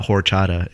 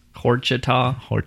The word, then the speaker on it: horchata: You don't pronounce the H. It's not horchata. It's horchata.